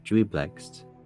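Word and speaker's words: Juiblex: It was very interesting that Jubilex is, that's how you pronounce his name, but it's pronounced Jubilex. It's J-U-I-B-L-E-X.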